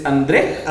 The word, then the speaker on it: Andrzej: ¿Es André. André. – Andrzej. En entrevistas he visto que le llaman André.